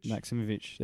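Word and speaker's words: Maksimović: Maximovic, there you go.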